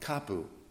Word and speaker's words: kapu: Before I talk about why Pu'uhonua was important to Hawaiians, I have to talk to you about kapu. Kapu kind of rhymes with taboo, and in fact, that's kind of the connotation that word is used even today in Hawaiian culture.